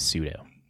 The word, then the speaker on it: pseudo: That word is pseudo. – Who enter this month's Arena Open. If it's pseudo, then why is there a P in it, sir? – It's silent. It's how you spell it.